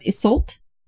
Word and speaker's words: Iseult: I'm going to say that this name is pronounced Isolt, I-S-E-U-L-T, Isolt? – Maybe very similar to like Isolde, like Tristan and Isolde, like Isolt. Okay. Yeah. – Okay, we're going to go with Isolt, and you'll tell me after you listen to it.